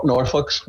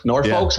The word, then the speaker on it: Norfolk: Nor- Norfolk's. Norfolk's.